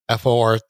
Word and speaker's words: Forza: really need everyone to know it's pronounced Forza. – Okay. – This is 2019. – Yeah. – Take it for what it is. – Yeah. But it, like, well, that, and again, that's from them, and you know, but they might be doing it based off the Italian pronunciation of it.